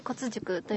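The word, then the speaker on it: 甲乙塾: その骨塾言ってます、ちょっと分かりにくいんで、字の方は甲乙の塾って書くんですけれども、そうですね、甲乙と書いて骨塾と読む、骨と読みまして、うん、でそれはなんで骨かというと、うん、まあ、いろんな職人さんの技術なんかのコツを学ぶということで骨塾という名前がついたと聞いております。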